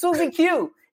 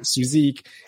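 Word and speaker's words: SuzieQ: Uh, yeah, they came up with all kinds of, and I was like, guys, where is your rock and roll history? It's Suzy Q. It's a CCR song.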